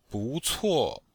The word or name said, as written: bu2cuo4